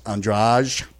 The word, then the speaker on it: Andrade: Well, it's spelled A-N-D-R-A-D-E, but it's pronounced Andrade.